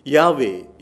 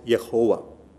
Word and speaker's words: Yahweh: Yahweh is how you pronounce it. Some call it Yehovah.